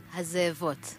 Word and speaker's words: Hazevot: So, yeah, wir haben hier gerade die Band Hazer Avot bei uns. Or do I pronounce it right? – Hazevot. – Hazevot. Hazevot. Now I'm a Hazevot.